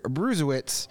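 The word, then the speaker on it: Brzustewicz: Do you know how to pronounce that last name? Because I sure as hell don't. – Bruzewitz, I believe. – Bruzewitz. – Right? – Okay. Hunter Bruzewitz.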